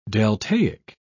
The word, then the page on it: deltaic: British English